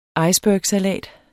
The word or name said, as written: icebergsalat